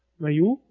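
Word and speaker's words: Mayhew: On avait comme acteur Peter Mahou. Je me rappelle plus comment on prononce. Mayu, voilà, qui incarne donc Chewbacca.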